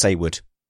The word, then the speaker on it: Saywood: It's pronounced Saywood. I actually write it down as S-A-Y-W-O-O-D in my notes so they don't get it wrong and invoke his anger.